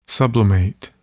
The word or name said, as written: sublimate